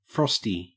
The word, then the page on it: frosty: Australian English